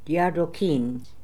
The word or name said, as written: Diardookin